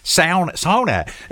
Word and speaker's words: sauna: The correct pronunciation is sauna.